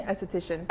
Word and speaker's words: esthetician: esthetician. Did I say that right? – Esthetician. – Esthetician. – Yeah. – I still didn't say it right. – Your S's are going together. It's okay, though.